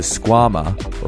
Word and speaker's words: Squama: The Squama, or rhymes with your mama, is a high-performance Velcro slipper aimed at getting you boulderers up your sickest project.